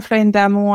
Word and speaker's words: Florine Darmon: I'm Florine Darmon. I'm the head of the marketing at Konica Minolta, France, and your pronunciation of my name is perfect.